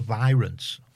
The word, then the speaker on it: Virants: A band called The Virants. – Okay. – Pronounced V-I-Rants. That's what it's...